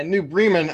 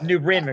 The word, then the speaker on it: New Bremen: Yeah, New Bremen, I found out, is the actual pronunciation. And New Bremen, excuse me. – Yes, but that's all right. I called them New Bremen during the game too, so I'm human.